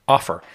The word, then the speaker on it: Offor: Now, Robert is the founder and CEO of a prop tech firm called Offer, and that's spelled O-F-F-O-R.